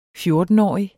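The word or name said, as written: fjortenårige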